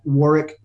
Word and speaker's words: Warwick: And on LinkedIn, we're at Warwick Fairfax. Warwick's name is spelled with that silent W in the middle. It's W-A-R-W-I-C-K, at Warwick Fairfax.